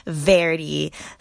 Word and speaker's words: Verdi: Later, we decided that the correct pronunciation is the Italian word for green, Verdi, so that's what we say now.